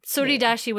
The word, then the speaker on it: Tsuridashi: Tsuridashi with a silent T, right?